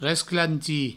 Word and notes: resclantir: resclanti; Provençal

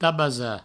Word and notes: tabasar: provençal; tabasa

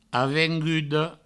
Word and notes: avenguda: avengudo; Provençal

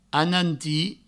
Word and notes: anantir: ananti

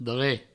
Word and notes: dre: Provençal

